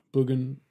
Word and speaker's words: bougainvillea: How do you pronounce it? Bougainvillea? – Bougainvillea. – It's the red bush that Joan Didion always talks about. – I always think it sounds like you're saying bougainvillea, but it's bougainvillea.